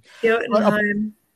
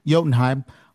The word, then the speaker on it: Jotunheim: But, you know, I did kind of enjoy seeing Loki rendered as an actual frost giant, as the heir to Jotunheim, if I've pronounced that correctly. – Jotunheim. – A part of me, sorry. Thank you, Jotunheim.